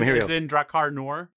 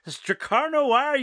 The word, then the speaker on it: Drakkar Noir: As in Drakkar Noor? – Yes, that's exactly how you pronounce it.